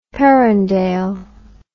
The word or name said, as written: Perendale